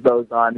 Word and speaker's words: boson: And when asked what that means, he says that, essentially what it means is that the creation of boson, am I saying that right? – The Higgs boson, yeah.